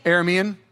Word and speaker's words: Aramean: Uh, the how do we say this word? Aramean? Hey, somebody give it to me. Aramean. Cool. I'll take it.